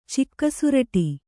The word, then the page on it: ಚಿಕ್ಕಸುರಟಿ: Kannada